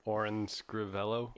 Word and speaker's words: Orin Scrivello: Orin Scrivello.